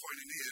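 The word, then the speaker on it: koinonia (κοινωνία): It's pronounced koinonia, and it means fellowship, but it also means contribution, participation, or sharing.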